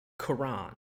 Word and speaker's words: Corin: It's pronounced Quran. – Right. – Okay. All right. – I'm not gonna say it that way. – It threw me for like 2 seconds. I was like, wait, are they talking about the religious text? Like it Yeah, no, it definitely like, they pronounce it like Quran. It's spelled like coral, but with an N, so Corin. – Yeah. – C-O-R-I-N. – Corin. – Yeah. – But they pronounce it and they say Quran.